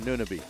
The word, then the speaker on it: Anunoby: Yeah, Anunoby.